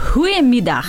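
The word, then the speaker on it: Goedemiddag: По-голландски, если правильно говорить, учитывая, что сейчас середина дня, то «Гуемидах».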